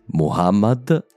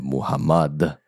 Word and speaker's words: Muhammad: La prima è proprio il nome più comune di tutti, Muhammad e non Muhammad. A quanto pare ho preso la pronuncia francofona del suo nome, scusate.